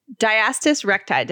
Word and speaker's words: diastasis recti: Diastasis recti.